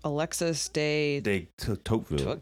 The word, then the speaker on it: Alexis de Tocqueville: Alexis de Tocqueville.